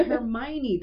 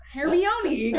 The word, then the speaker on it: Hermione: Oh, Hermione. That's not what it looks like. Hermione.